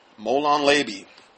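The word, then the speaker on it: Molon labe: Molon Leiby. That's how you say it.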